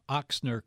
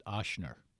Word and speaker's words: Ochsner: That commercial that just ran there, it was nice to hear that they pronounced Ochsner correctly. It's not Ochsner.